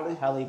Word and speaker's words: Halle: I think it's Haley. – Halle Bailey.